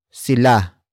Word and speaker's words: sila: Another example, nangabayo sila sa Baguio. Nangabayo sila sa Baguio. Nangabayo sila sa Baguio. It means they rode horses in Baguio. Word for word it's nangabayo sila sa Baguio.